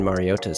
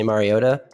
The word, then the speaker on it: Mariota: The correct Hawaiian pronunciation of Marcus Mariota is to enunciate the T. And if I'm talking really fast, I'll say Mariota, like it has a D.